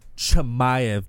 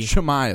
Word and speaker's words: Chimaev: And don't forget that name, and it's pronounced Chimaev, baby. – Chimaev.